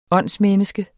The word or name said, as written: åndsmenneske